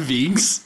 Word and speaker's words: Vinks: I'm Vinks.